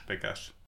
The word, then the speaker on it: Pekař: Pekar.